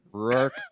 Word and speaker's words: Rourke: Rourke. – Go ahead, buddy. – I love Rourke. – I almost RC. – RC Cola. I'm going to start calling him RC Cola. Rourke Chartier.